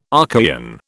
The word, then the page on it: Archaean: British English